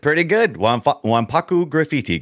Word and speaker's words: Wanpaku Graffiti: And if you pronounce, I hope I get this right. Splatterhouse Wanpaku Graffiti. – Pretty good. Wanpaku Graffiti, correct.